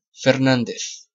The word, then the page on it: Fernández: [feɾˈnandeθ]; European Spanish